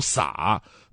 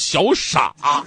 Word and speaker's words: 撒: 个文化人啊说这个，哎呀，这个呃小萨老师你那个音呢不读萨，应该读三声撒啊，呃，撒贝宁老师当场脸色就变了，他说难道我自己还不知道自己叫什么吗？其实他也知道，他那个姓吧，在姓里边应该读撒，但是在他们老家呢，平卷舌不分，小撒就总被误读成小傻，就导致他妈妈跟学校老师说：“哎呀，你干脆啊，就就别叫姓了，就叫他那个呃贝宁就行啊。”